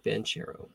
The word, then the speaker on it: Banchero: Bronchero? Or is it Bronco? Okay. – Paolo Banchero is the number one overall pick.